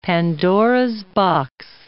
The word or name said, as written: Pandora's box